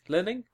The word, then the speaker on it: limning: L-I-M-N-I-N-G. – Limning? – Limning. Huh. Well, I'm going to pronounce it like damning, but with an I instead.